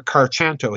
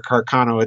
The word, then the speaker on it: Carcano: They have now said that this is a 6.5 Mannlicher Carchanto. He mispronounces it, and it's right there on the documentary, Mannlicher Carchanto. And of course, this is the alleged murder weapon, the Mannlicher Carcano Italian carbine from World War II with the misaligned sight.